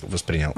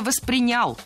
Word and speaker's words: воспринял: Ну, если вот мне по-простому, да, объяснить, вот я бы это так воспринял. – Да? – Что это не приставка. – Было сказано «воспринял», но это неверно. Правильный вариант – «воспринял». Ударение в глаголе ставим на второй слог – «воспринял».